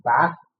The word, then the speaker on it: Bath: I'm a pastor of a church in a place called Bath, or as I pronounce it, Bath.